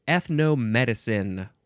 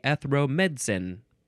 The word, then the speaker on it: ethnomedicine: The next word is ethnomedicine. One word. The Brits like to say, they like to cut out a whole syllable. They like to say ethromedicine. Ethromedicine.